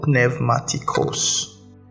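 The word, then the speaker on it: pneumaticos (πνευματικός): The Greek word there is, the Greek word translated spiritual is the word pneumaticos. Now some modern days translators pronounce it as pneumaticos, but it's not pneuma, it actually is pneuma, because the P is not silent.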